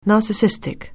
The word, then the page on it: narcissistic: anglais